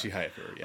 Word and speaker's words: Chihayafuru: Maybe that's just my own personal preference, but I mean, even Chihayafuru, that's how you pronounce that. – Chihayafuru, yeah.